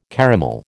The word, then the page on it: caramel: American English